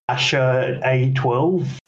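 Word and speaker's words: X Æ A-12: And it's pronounced something like Asher A12.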